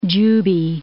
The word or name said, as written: Juby, Cape